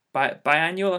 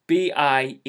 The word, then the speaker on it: Biennale: Biennial, I think it's not biennial, but it's Biennale. How would you pronounce that? Biennale. – Biennale. Yeah. – Double N. A L E.